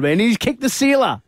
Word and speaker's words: Uwe Seeler: Uwe Seeler, the German. Is it Seeler? I was going to say Seeler. – Yeah, Uwe Seeler, yeah.